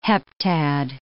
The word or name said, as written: heptad